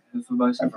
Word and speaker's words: Hefeweizen: what is the proper cup for a Hefeweizen? Hope I pronounce that right. – Yeah, Hefeweizen. – Yeah.